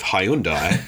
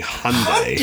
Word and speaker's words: Hyundai: my pronunciation of Hyundai, and obviously growing up in Australia where that is how you pronounce it. This is the first time I'd ever come across someone saying Hyundai.